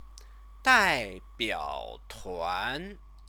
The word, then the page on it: daibiaotuan: chinese